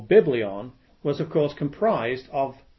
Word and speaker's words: biblion: Well, Bible comes from the Greek term biblion. You would spell that B-I-B-L-I-O-N.